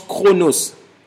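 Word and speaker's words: chronos (χρόνος): Quand il dit le mystère caché de tout temps, dans le livre de Colossiens, le mot « temps » ici, c'est un mot grec que l'on prononce « chronos ».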